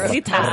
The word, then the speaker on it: Rosita: You say it right after roll the arm. – Yes. – Rosita.